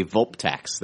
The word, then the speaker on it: Vulptex: If one of these Voltices is called a Vulptex. That's the singular pronunciation.